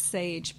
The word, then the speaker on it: Saje: And I'm like, no, actually it's Saje.